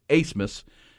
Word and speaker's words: Abmas: It's spelled A-B-M-A-S, but it's a German name, so it's pronounced Asmus.